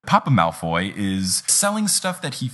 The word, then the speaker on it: Malfoy: Also, fun fact, I've been pronouncing Malfoy Malfway the whole time. I thought that's what it was. I don't know why. And then I was like, wait, it's spelled Malfoy.